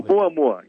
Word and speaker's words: Oumuamua: You found this 11-day, this object, which you believe is, and I'm talking about the Umuma. I hope I pronounced it correctly. – Oumuamua, yes. – That's in Hawaiian.